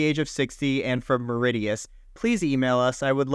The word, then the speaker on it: Mauritius: Okay, I just looked it up. It's pronounced Mauritius.